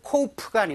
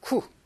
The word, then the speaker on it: coup: 이건 코프가 아니고요. 쿠, 쿠라고 읽습니다. 피 발음이 되지 않고요. 우리가 군사 쿠데타라고 할때 영어로는 쿠라고 적고 있습니다.